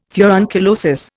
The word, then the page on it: furunculosis: Indian